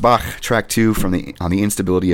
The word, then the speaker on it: Bach: You like how I pronounce Bach correctly, by the way, Levi? – Yeah, I do, dude.